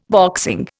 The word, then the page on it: boxing: Indian